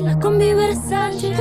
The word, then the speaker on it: La Combi Versace: C'était La Combi Versace de Rosalía. Et oui, j'ai réussi à le prononcer.